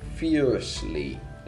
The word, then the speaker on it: fiercely: Sexta questão, nós temos aqui qual é o significado da palavra fiercely. Lembrando que esse R é como se você não pronunciasse ele. Fiercely.